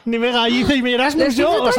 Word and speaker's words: Nimega: ¿Se escribe ¿cómo se dice? – Nimega. Ya, es se escribe Nijmegen, ¿vale? – Nijmegen, Nimega.